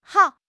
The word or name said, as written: hao2